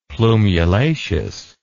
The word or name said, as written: plumulaceous